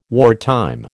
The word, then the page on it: wartime: American English